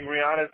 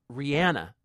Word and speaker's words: Rihanna: It's the hard H. I always pronounce the hard H that throws me, but it's not how it is. It's Rihanna. Is that right? – It's not Rihanna. – It's not Rihanna. It's Rihanna? Okay.